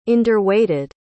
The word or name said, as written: underweighted